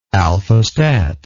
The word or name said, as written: al fustat